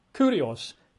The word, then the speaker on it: kurios: We're reading an English translation, but the word Lord there in verse 12 is the Greek kurios, K-U-R-I-O-S, at least in English letters, K-U-R-I-O-S, pronounced, I think, properly, kurios, right?